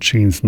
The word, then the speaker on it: Jeans: Und Jeans nicht wie die Hose, sondern wie die Gene. Ich denke, das spricht man auch so aus.